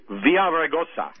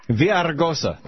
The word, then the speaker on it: Villaraigosa: Villaraigosa. – Villaraigosa.